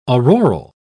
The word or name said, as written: auroral